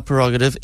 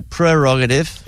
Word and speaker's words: prerogative: Then we all pronounce the Bobby Brown title, My Prerogative, incorrectly. It's My Prerogative.